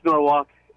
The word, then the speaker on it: Norwalk: You don't pronounce the L, or do you? – No, it's Norwalk.